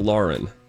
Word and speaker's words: Lauren: Her name is Lauren. – Lauren. – You don't have to emphasize it. It's spelled L-A-U-R-E-N, not L-O-R-E-N. Lauren. It's Lauren.